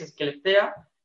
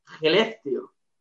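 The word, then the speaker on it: Skellefteå: como se ve que es Esquelestea, pero en realidad como se pronuncia es Gelestio.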